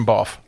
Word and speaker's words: Beauf: So the F is not silent? – No. – Okay. Generation Beauf. – Or the B? – The B is silent.